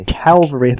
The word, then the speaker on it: cavalry: Cavalry, there you go. – V first, L last. – Okay.